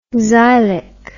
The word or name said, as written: xylic